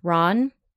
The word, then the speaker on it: Ran: There's also Akira Kurosawa's 1985, I believe it would be pronounced Ran because it's Japanese, so R-A-N, and Ran means mm-hmm.